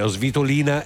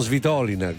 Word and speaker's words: Svitolina: Svitolina e non Svitolina, come magari Saccari e non Saccari.